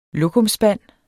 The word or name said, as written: lokumsspand